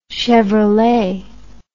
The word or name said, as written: Chevrolet